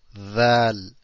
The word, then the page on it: ذ: Dhal